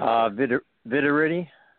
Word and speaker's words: Vitiritti: Vitiritti.